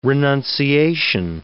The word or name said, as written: renunciation